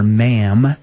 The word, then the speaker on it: ma'am: But, no, we'll be able to call her Catherine, or ma'am, which the article I found had, they never have pronunciations where I get my information at, except for this one. For ma'am, they have in parentheses, like ham.